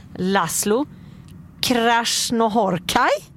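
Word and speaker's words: László Krasznahorkai: Då är, nu ska vi se om man kan uttala det här rätt, förra årets pristagare Laszlo Krasznohorkai. – Ja, det är utomordentligt bra.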